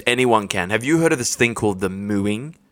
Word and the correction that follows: mewing: Sorry, I think I pronounced it wrong. It's mewing. – Mew, like M-E-W-I-N-G.